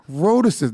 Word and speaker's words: rhotacism: It's roticism. Speech impediment is defined by a lack of ability or difficulty pronouncing the R sound. Yes. Rhotacism. They can't even say their own disease name.